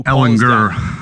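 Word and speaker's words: Ehlinger: Who Ellinger, with the hard G. – Right. – Hard G. Yes.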